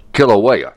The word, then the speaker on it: Kilauea: Kilauea.